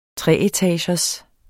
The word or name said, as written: treetagers